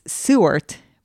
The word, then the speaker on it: Seward: It's Seward, but I say it's Seward the whole time. I'm a name butcher, just putting that out there. And so people have corrected me. It's spelled like Seward, right? – But it's pronounced Seward.